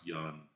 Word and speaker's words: Yeun: Or actually, I believe it's pronounced Yun. but somebody told me it was Young.